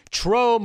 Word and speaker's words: Tro: Tro rhymes with Joe. Tro moves on to Jacques Vaughn,